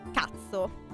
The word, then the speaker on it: katso: Tipo la parola cazzo. – Ma proprio, cioè, così? – Si scrive K-A-T-S-O. – Non stiamo dicendo una parolaccia.